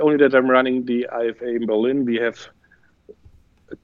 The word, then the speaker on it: IFA: I'm running the IFA in Berlin, or IFA as you say. It's a German pronunciation.